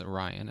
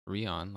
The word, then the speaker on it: Orion: And but we start off on the planet Sonata, and Ryan, who this time I have learned that it's Orion and not Rion, like I was mispronouncing when we did our Renegades podcast.